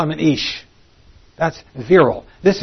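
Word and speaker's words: Ish (אִישׁ): This word is pronounced Ish.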